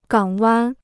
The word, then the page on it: 港湾: gǎng wān